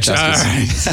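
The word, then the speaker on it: Chasquis: And wasn't there two Chaskis, am I saying this right? – Mm-hmm. – Okay, yeah. Chaskis.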